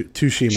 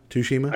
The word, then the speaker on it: Tsushima: But and then like for whatever reason goes to Shushima. Shush, I can't say that right now. – T- Tushima. – Shushima. Tushima. Uh, Tushima.